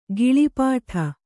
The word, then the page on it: ಗಿಳಿಪಾಠ: giḷi pāṭha